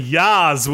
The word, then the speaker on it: Yaz: And it's Yaz. – Yeah. – It's a hard A.